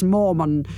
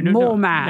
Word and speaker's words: Mormon: You said it right the first time, dear. – Because it's M-O-N at the end, isn't it? – Yes. – Not M-A-N, like a superhero. – No. – More man.